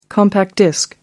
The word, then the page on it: compact disc: American English